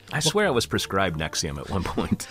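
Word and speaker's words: NXIVM: says, it's like a sexier Nixium. Is that how you actually pronounce that? The real cult? – Nexium. – Nexium.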